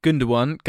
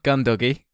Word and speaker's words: Gundogan: Gundogan, Gundoggy, De Bruyne, The Bruner, Pantamillion, and Jekko, just call him Deco.